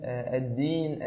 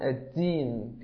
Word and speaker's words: الدين: فتفضل يا أخي الحبيب وانتبه إلى كسرة الكاف، هو دال الدين، الدين، انطقها بهذا الشكل. ولقد كنت أستمع إلى بعض المشايخ أحيانا في الصلاة يقولون الدين، كأنهم ينطقون الدال تاء لاشتراكهما في المخرج، ولكن هذا طبعا يعني خطأ شديد.